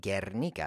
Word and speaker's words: Guernica: It's called Guernica because that U is silent. It's just serving the function of forcing the hard G sound.